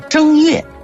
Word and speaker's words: 正月: 正月和嬴政的正是同音字，也要避讳，就把正改成读第一声叫正月。